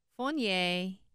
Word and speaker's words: Fournier: That's not how you say it. How do you say that? I'm terrible at French. – Fournier. Yeah, Pierre Ifils, father and sons.